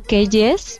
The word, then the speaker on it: Keyes: Bueno, se escribe Marianne Keyes, pero se pronuncia Keyes.